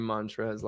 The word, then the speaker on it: Montrezl: So his last name is H-A-R-R-E-L, but that's not really important. His first name is spelled Montrezl. The L is silent. – Z-L? – Yeah. – Z-L. – Z-L. – Montrez. Like Montrezl, yeah, but you don't say the L.